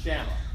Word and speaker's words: Shammah: I think it's really how it probably should be pronounced. Shammah.